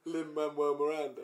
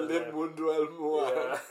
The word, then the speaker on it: Lin-Manuel Miranda: Lin-Manuel Miranda. – Yeah, that's the one. So I can't say Lin-Manuel Miranda.